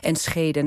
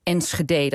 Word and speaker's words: Enschede: Maar dat zou misschien wel geholpen kunnen hebben in die ontwikkeling van Enschede naar Enschede. Dat dus uiteindelijk gaandeweg de standaard uitspraak.